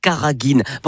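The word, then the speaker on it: carraghénane: Ça s'écrit C-A-2-R-A-G-H-2-E-N, mais ça se prononce bien Karagin.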